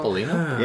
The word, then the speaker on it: Topolino: Topolino? – Yeah. – How do you spell it? – T-O-P-O-L-I-N-O. – Oh, my God. Can you not, please, Matt? – T-O-P-O-L-I-N-O.